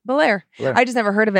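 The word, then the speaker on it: Bellaire: Yeah, emphasis on the second part. Because I was going to say it like California, the Prince of Belair.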